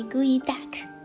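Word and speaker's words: geoduck: 那我刚才也提到哈，我们发音为 gooey duck，就是那种黏糊糊的鸭子。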